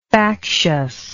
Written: factiousness

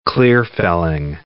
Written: clear-felling

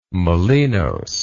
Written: Molinos

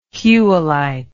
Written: whewellite

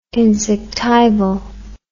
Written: insectival

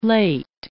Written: late

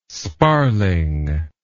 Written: sparling

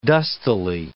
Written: dustily

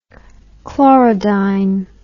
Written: chlorodyne

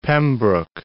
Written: Pembroke

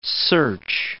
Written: search

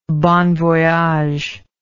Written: bon voyage

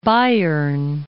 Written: Bayern

